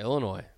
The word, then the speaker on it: Illinois: So it's Illinois. The S and the E is silent. – Yeah, Illinois.